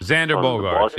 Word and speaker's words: Xander Bogaerts: And the one that looks potentially could come to the Mets, and I may be butchering his name, Xander Bogaerts. – Xander Bogaerts, yeah.